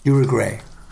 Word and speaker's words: Uruguay: Uruguay. Uruguay, it's pronounced, and it's La France.